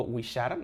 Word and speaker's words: wixar: O por ejemplo, esa palabra, eh, entre comillas, no la pongo, wishar, ¿no? Que se escribe W-I-X-A-R, significa, vaya, hacer pipí, ¿no?